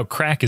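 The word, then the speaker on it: craic: What I love about this is how crack is spelled. It's C-R-A-I-C. I mean, it's not C-R-A-C-K.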